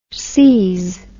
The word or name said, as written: sees